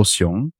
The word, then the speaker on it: Xiong: It's, if you mispronounce it, or because Chinese language, one word can be pronounced in the same way, but it means different things. So when I was in school, my teachers loved to call me a bear. A Xiong. – Oh. – But the actual pronunciation for my name is also Xiong, but it means masculine.